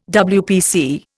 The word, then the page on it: WPC: Indian